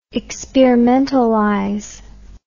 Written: experimentalize

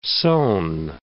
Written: sown

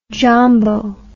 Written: jambo

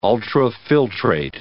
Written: ultrafiltrate

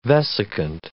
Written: vesicant

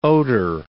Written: oater

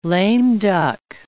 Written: lame-duck

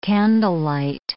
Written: candlelight

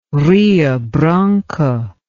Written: Rio Branco

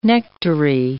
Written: nectary

